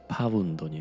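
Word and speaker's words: pound: ポンドこれはまあイギリス風に発音するとパウンドになる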